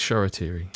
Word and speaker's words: Shoretire: So Shola Shoretire, again, it's spelt Shoretire, T-I-R-E, but we're fairly sure on the Shoretire, so let's stick with that. – It's Shoretire, yeah.